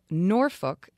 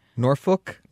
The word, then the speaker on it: Norfolk: The second syllable of Norfolk is pronounced to rhyme with cook, but there's no reason why you would have known that.